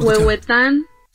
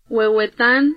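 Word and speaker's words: Huehuetán: Huehuetan. – Say it again. – Huehuetan.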